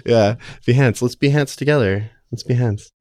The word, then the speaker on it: Behance: It's pronounced Behance, by the way. Behance.